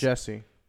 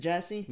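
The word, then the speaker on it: Jussie: Juicy? How do you spell it then? Juicy Smollett? I'm sorry, pronounced guys. – Jesse. – Is it Jesse? – Yeah.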